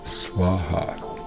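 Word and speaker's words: Swaha (स्वाहा): Now, when this love goes back to source from your side, think Swaha. like swan and ha-ha, swan and ha-ha, like swaha, swaha.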